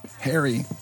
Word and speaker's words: Hari: It's spelled H-A-R-I. – Harry.